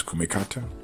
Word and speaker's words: kumikata: It's kumikata, kazushi, sukuri, and kake. You spell kumikata K-U-M-I-K-A-T-A. Kazushi is spelled K-U-Z-I-S-H-I. Sukuri is spelled T-S-U-K-U-R-I. And kake is spelled K-A-K-E.